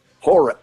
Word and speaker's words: hora: First of all, am I saying it right? Hora. – Hora. H-O-R-A.